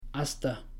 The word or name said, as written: hasta